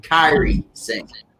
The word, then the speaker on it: Kairi Sane: Nia Jax versus Carly Sane. I hope I got that name right. – Andrew, I tell you this. It's pronounced Kyrie Sane.